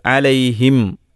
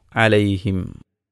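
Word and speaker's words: അലൈഹിം (عليهم): അലൈഹിം എന്ന് പറയുമ്പോൾ ചുണ്ട് പരസ്പരം ടച്ച് ചെയ്യുന്നു, പൂട്ടുന്നു. അലൈഹിം.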